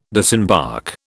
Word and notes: disembark: British English

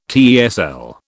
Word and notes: TESL: British English

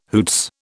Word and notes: hoots: British English

